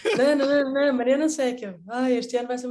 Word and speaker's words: Secca: Sim, tu uh, adiantaste o teu apelido, uh, que se escreve S-E-C-C-A, Seca mesmo? Exatamente. Seca mesmo.